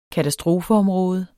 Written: katastrofeområde

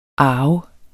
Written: arve

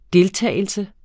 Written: DELTAGELSES

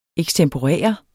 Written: ekstemporere

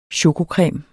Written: chokocreme

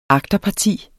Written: agterparti

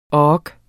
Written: ork

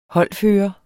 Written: holdfører

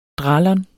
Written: dralon®

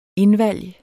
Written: indvalg

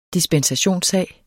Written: dispensationssag

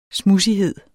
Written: smudsighed

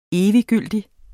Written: eviggyldig

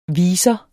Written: viser

